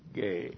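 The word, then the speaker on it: gay (γῆ): That's the accusative case of the noun gay, G long E, gay.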